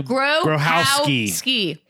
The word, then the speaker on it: Grohowski: Grohowski. – Jason Grohowski. – We finally figured out three syllables. – How to pronounce the last name.